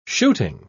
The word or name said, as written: shooting